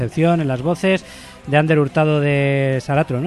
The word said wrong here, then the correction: Saracho: ¿no? – Saracho, Saracho, sí.